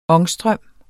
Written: ångstrøm

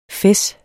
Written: fes

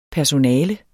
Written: PERSONALERNES

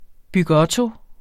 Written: bygotto

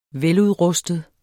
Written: veludrustet

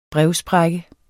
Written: brevsprække

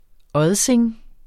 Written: odsing

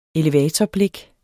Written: elevatorblik

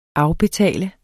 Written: afbetale

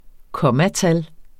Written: kommatal

